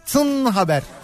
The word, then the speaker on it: TINN: Yani bunu böyle T I N ya da T I N N haber falan diye de telaffuz edebilirsin de. Türkçe okuduğun zaman şöyle oluyor. Tın haber.